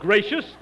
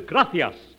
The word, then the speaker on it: gracias: No, not gracious. That word is gracias. You see, in Spanish, the C is pronounced T-H. Look, gracias. You see? T-H. You see? – Would you mind putting your head some...